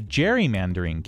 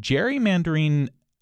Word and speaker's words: gerrymandering: that gerrymandering technically should actually have a hard G.